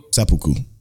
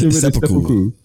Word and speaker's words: seppuku: What did you think about Baji's Sudoku? – Seppuku? – No, I think I said it right. – Sudoku is a math game. – No. – A Pokemon math game.